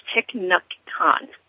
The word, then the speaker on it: Thich Nhat Hanh: Um, it, and, and I had to actually Google the pronunciation of, of this. Um, I've, I've read things by this Buddhist monk uh, quite a few times, but I never had any idea how to say the name. But it's Thich Nhat Hanh, and um, the quote is, the most precious gift we can offer others is our presence.